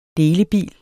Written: delebil

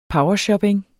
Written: powershopping

